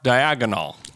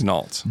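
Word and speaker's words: Daigneault: And if they listened to the broadcast, they would say, Mark Diagonal. – I don't know what's the correct. I've been so brainwashed by everybody else's pronunciation. – It's Degnault. – Degnault. – That's how you say it.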